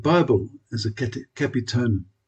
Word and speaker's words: capitonym: And there's a quaint English word called capitonym, and I didn't even know how to pronounce it, C-A-P-I-T-O-N-Y-M, capitonym, which means a word that changes its meaning when it is capitalized.